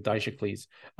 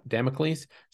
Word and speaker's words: Damocles: I don't know who the next manager would be, and it definitely doesn't rhyme with Dicecles, Damocles.